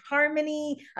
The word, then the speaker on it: Harmonie: It's Life with Harmonie, about bringing more harmony into your life.